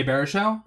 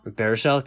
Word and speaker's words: Baruchel: Baruchel. – Oh, Jay Baruchel? – Baruchel, thank you.